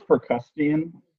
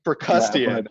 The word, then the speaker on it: Procrustean: I saw a Procrustean bed. – Yes. – Am I saying that correctly, first off? – I'm pretty sure it's Procrustean. – Procrustean.